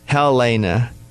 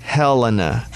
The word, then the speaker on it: Helena: Most people say Helena, but it's Helena.